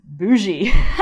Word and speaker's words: Bugey: It's S-A-V-O-I-E. It's a region in pretty far east France. And a fun fact is that a sub-region of the Savoie is bougie. Spelled B-U-G-E-Y. But I was reminded of Mondus because I just drank a bottle of bougie Mondus from Maison Angelot, or Angelo.